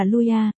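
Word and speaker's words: Luya (陆压): Tên Lục Áp ở Trung phát âm là Luya,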